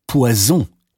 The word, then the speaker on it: poison: Manchmal kann die Verwechslung stimmhafter und stimmloser Konsonanten bei der Aussprache auch fatale Folgen haben. Zum Beispiel die Verwechslung von poisson, Fisch, mit poison, Gift.